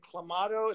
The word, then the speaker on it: Clamato: I went with the Bud Light and Clamato. Is that how you say it? I don't know. – Yep, it is.